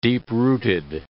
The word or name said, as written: deep-rooted